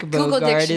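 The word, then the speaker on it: Bogarde: It is Dick Borgant. – Can somebody Bogard. – Get it? – Bogard. I'm so sorry. – Dirk, yeah, it would be Bogard.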